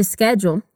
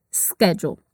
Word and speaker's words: schedule: No inglês americano, ela é pronunciada dessa maneira que nós já falamos, schedule. Mas no britânico, a gente diria schedule, com esse som de sh, igual de chá ou chuva.